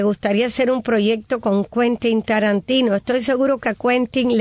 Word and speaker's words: Quentin Tarantino: Quentin Tarantino. ¿Es así? ¿Cómo se pronuncia, Teudy? – Sí, es así. – Quentin Tarantino.